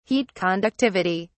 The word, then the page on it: heat conductivity: British English